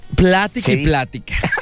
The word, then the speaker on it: plática: Platique. – Ah, bien a gusto. – No, pero acuérdate que no se dice así. – Plática. Sí, es correcto.